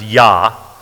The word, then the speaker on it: Jah: Well, here's a word that only appears one time in the Bible, and that is God's name, Jah. Or they pronounce it many times, Yah, like in Yahweh. We say Jah as in Jehovah.